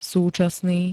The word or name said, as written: súčasný